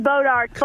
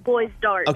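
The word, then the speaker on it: Bois D'Arc: It's pronounced Bodark, but it's spelled Boys Dark.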